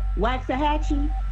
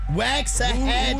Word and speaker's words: Waxahachie: Waxahachie? – Waxahachie.